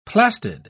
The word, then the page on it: plastid: British English